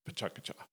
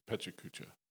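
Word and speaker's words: pecha kucha: people talking in the background, that's pachakacha to Japanese. That's what that means. – Sounds really cool. – And so how do you say it? English speakers, readers almost universally see the word and say it just how we think it should sound, which is pechakucha. And you're perfectly fine saying that.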